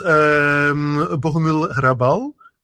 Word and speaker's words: Bohumil Hrabal: Well, for instance, Bohumil Hrabal, I like a lot. Do I pronounce it correctly? Or? – Very good pronunciation.